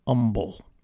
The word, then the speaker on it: Humble: Now, I learned very early on after moving to Houston, they definitely knew I wasn't from around here real quick, because Humble is pronounced just like that, Humble, not Humble, like I pronounced it. The H is silent, Humble.